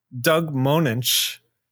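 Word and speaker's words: Moench: Doug Much. I butchered his name again. We're going to have to send him another edible arrangement. Doug Monench, who was writing Batman at the time, wrote the Catwoman story in there with Ed Hennigan on art.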